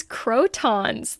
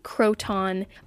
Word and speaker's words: croton: It looks like croton, but I'm really glad that its name is croton, because croton sounds like crouton, and it's always bugged me that it sounds like a salad topping.